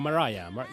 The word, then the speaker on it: Mariah: María, pero no se pronuncia María, sino Mariah.